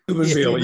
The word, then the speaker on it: Steubenville: But when we met our Akron brothers and even, you know, Steubenville, is it? Did I say it right? – Steubenville, yeah.